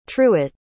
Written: truett

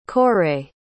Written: coire

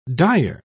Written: dire

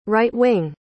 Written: right-wing

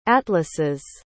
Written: atlases

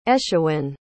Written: aescwyn

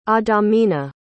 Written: adamina

